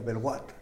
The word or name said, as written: belwat